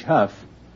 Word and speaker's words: Hough: No, Sue is S-U-E, like Sue St. Marie, which actually is spelled S-I-O-U-X. You say you pronounce H-O-U-G-H, Huff. How do you pronounce D-O-U-G-H? – That's Doe.